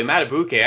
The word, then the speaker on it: Madubuike: Madubuike, I thought you nailed the pronunciation there.